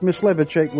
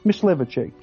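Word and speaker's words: Mysliveček: Okay, Joseph Myslevicek was a Bohemian composer. All right, let's just... M-Y-S, Myslevicek, Joseph Myslevicek. How about Sarti, S-A-R-T-I?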